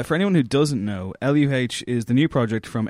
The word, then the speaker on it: LUH: It's pronounced Luh. – Is it pronounced Luh? – He pronounces it Luh, but then again, he doesn't pronounce anything correctly, so I don't think he can go by, you know.